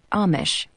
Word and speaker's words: Amish: She pronounces the word Amish with a long A.